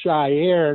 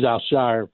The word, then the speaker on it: Al-Shaair: So many people are calling him Shaair, and I put a thing out on Twitter and say it's one syllable. It's not Shaair.